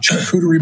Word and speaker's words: charcuterie: I was at a friend's house, had a little cured meat from a charcuterie board.